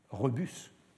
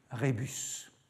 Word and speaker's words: rebus: Les bons latinistes disent d'ailleurs rébus pour la devinette, avec la prononciation restituée, et ils disent rebus pour l'ablatif latin. Mais un bon latiniste aujourd'hui ne prononcerait jamais rébus.